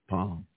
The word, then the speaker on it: Psalm: 23rd Psalm. – That's for those that don't know that the P is silent.